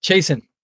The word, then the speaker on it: Chaisson: I've got the wonderful Tim, where we're just pronouncing it, Chaisson, whatever you're having yourself, but you say Chaisson. – Chaisson, yes. Yeah, that's how we say it here, but I'll say Chaisson because that's how it's spelled.